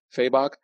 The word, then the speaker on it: Fabok: Fabok.